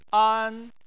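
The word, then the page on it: an: tone 1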